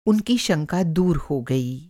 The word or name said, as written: शंका